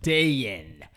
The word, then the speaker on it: Dayin: So there's the non-Chinese way of pronouncing it, which is Dayin, because it's D-A-Y-I-N, and that's actually what I go by in America, because no one is gonna pronounce my Chinese name.